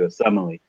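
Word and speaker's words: Osemele: I know I'm saying that wrong, but the guard from the Ravens. Thank you, Asemele.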